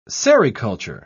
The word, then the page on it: sericulture: British English